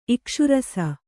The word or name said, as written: ಇಕ್ಷುರಸ